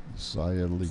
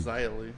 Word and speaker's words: Xia Li: Mercedes Martinez versus, how does she pronounce her name? – Xia Li. – Xia Li. – That's what I said, Xia Li.